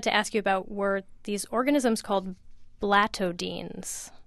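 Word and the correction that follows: blattodean: Blattodians, yes.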